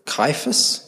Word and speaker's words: Caiaphas: It says, Those who arrested Jesus took him to Caiaphas. Is that how you say it? Yep, good.